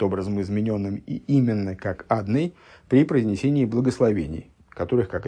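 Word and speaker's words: Адонай: И, с другой стороны, а почему он читается именно как Адный? Ну вот, да, мы сказали, что есть другие случаи прочтения этого имени при другой огласованности, но в основном читается как Адный.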